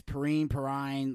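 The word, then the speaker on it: Perine: I think it's Perrine. Like, it's spelled.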